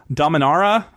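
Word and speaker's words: Dominaria: It's Dominara. I think that's how you pronounce it. There were several more syllables and vowels than I gave it credit for.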